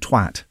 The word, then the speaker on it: Twat: You know, there's a village called Twat. – Yes, with two T's, I think. – Okay, I emphasise the second T in the pronunciation of it.